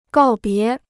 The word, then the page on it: 告别: gào bié